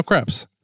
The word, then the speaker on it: Kreps: And my name's now Kreps. – What prompted the change in your name? Kreps? Am I saying that right? Kreps? – Yeah, it's French for a thin pancake.